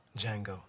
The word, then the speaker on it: Django: Django. – The D is silent.